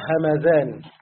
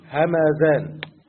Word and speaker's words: همذان: همذان دي موجوده في ايران وتنطق همذان، يعني بالذال المعجمة المفتوحة همذان، لكن فيه في أسانيد أخرى همدان بالدال، الدال المهملة وليس بالذال المعجمة، والميم فيها ساكنة فعكس همذان الميم فيها إيه؟ مفتوحة.